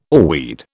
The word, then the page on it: oarweed: British English